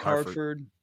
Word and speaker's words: Hartford: You got obviously Hartford. Is that right? Did I say his name right? Why that sounds so weird? – Malik Hartford.